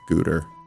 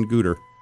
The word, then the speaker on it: Goethe: Like, I always heard his name when pronounced in English, that it's seriously comes out as like Guter. – How is it spelled? – The same way. G-O-E-T-H-E.